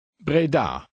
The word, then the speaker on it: Breda: In number 14, the stress is on the last syllable, the da. – Try to read number 14. – Breda.